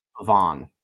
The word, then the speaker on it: Pavon: I don't know how to say his name. Pavon? – Pavon? I believe it's Pavon. – Because he's French.